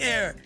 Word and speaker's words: Aire: She told fans on IG his name is A-I-R-E, pronounced as Air.